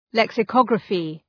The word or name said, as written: lexicography